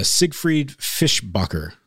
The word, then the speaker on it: Siegfried Fischbacher: So first round of news is Siegfried Fischbacher. Did I say that right? – Yep.